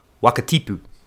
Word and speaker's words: Wakatipu: Yeah, for this week's pronunciation, because actually it is pronounced Wakatipu, because as we know, the Maori vowels are a, e, I, o, u. So once again, that would be Wakatipu.